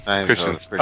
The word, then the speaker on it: Christensen: Wait, how do you say that? Christanis? How do you say it? You know. – You're asking me how to pronounce something? Come on. – Christensen? Christensen? – Christensen. – Christensen. Oh, it is Christensen. That's a fucked up way to spell Christensen, isn't it?